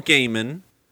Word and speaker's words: Gaiman: Uh, it's Neil Gaiman.